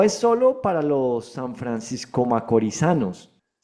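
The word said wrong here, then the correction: francomacorisanos: ¿Se dice así? ¿Lo dije bien? – Franco Macorizado. – A Franco Macorizanos. Bueno, ahí voy aprendiendo. No es solo para los Franco Macorizanos, sino para todos los inversionistas en la República Dominicana.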